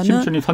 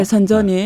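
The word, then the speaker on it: 선전 (深圳): 경우는 하나는 심천이 선전이죠. – 예, 선전이 네. 선전 정확하게 말하면 아. – 선전이에요.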